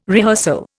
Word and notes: rehearsal: Indian

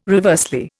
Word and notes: reversely: Indian

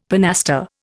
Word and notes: pinaster: Indian

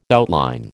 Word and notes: outline: American English

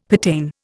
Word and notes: pertain: Indian

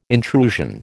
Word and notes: intrusion: American English

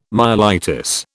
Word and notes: myelitis: British English

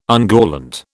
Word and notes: ungallant: British English

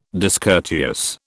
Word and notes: discourteous: British English

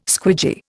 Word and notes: squidgy: Indian

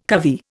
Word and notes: covey: Indian

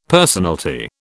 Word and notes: personalty: British English

